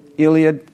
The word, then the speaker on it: Iliad: Homer's Iliad? How do you say it? Iliad. I was close.